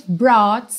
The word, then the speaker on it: brought: Também não se deixe confundir aí por esse GH. Para quem está ouvindo no podcast, brought, que é o passado e o particípio do verbo to bring, trazer, é escrito B-R-O-U-G-H-T, mas a pronúncia é bem simples. Brought, brought. Ok?